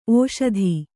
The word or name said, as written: ಓಷಧಿ